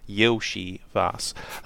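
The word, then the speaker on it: Iosivas: You could perhaps throw in an Andre Yoshivas. Yoshivas is spelled I-O-S. Yoshivas.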